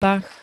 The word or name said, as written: pach